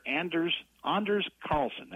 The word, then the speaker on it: Carlson: But this younger brother, Anders Carlson, that's the way he pronounces it,